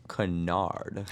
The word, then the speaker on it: Kennard: Kennard.